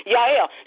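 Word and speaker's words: Jael: It's spelled J-L, but it's pronounced Yael.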